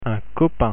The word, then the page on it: copain: French